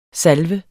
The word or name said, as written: salve